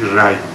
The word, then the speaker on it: ری: دو بیت شده رزال و گهی یافت افراسیاب پر آمد و آرام و از خرد و خواب بیاورد لشکر سوی خار ری. البته تلفظ درستش ری، میدونید در عوستایی به رگت رگ از ریشه رعه عوستایی یعنی درخشش.